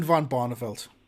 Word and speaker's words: van Barneveld: Raymond van Barneveld. Now, is Raymond van Barneveld... Van Barneveld. Yes, that's how you say it.